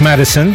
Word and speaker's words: MDSN: Here's a band, MDSN, pronounced Madison. For some reason, it's capital N, capital D, capital S, capital N.